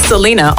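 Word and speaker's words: Selina: Selena Albright.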